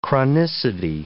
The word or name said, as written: chronicity